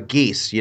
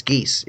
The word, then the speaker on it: geas: And for our listeners, that's spelled G-E-A-S. – Yeah, it's as the D&D spell, Geas Quest. – Not a waterborne fowl.